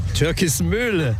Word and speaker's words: Türkismühle: Türkismühle wollte ich noch. – Türkismühle. – Türkismühle. Die Betonung ist anders.